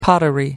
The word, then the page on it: pottery: American English